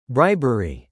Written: bribery

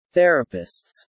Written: therapists

